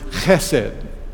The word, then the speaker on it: chesed: And that word is chesed. And I'll spell it for you. It is spelled E-S-E-D. Now, C-H-E-S-E-D is the way we would sort of transliterate that. Chesed.